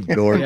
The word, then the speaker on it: Dort: Lou Dort. Yeah, Dort. – Yeah. – So it's like it rhymes with dork.